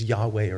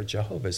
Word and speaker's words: Yahweh: And that word in the Hebrew is connected to God's personal name, which we would pronounce as Yahweh or Jehovah.